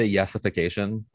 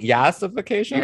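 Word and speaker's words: yassification: I think you're saying it wrong. – Am I saying it? Yesification? – Yeah, there it is. – Yesification. – There we go.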